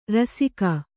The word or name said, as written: ರಾಸಿಗೈ